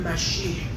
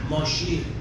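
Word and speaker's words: مشیح (משיח): اونا سین ندارن، چون حروف عبری 22 تا حرف داره، بعضی از حروف رو ندارن، مثلا سین ندارن، به جاش شین تلفظ میکنه، مثلا سلام رو میگن شلام یا شلوم، لذا مسیح رو میگن مشیح، ماشیه، ماشیح، همون مسیحیه که ما میگیم.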